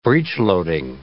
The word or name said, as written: breech-loading